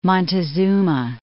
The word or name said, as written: Montezuma Castle National Monument